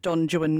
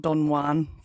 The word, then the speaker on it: Don Juan: He pronounced it Don Juan, not Don Juan, for some reason.